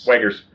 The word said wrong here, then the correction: Wits and Wagers: You're probably mispronouncing it.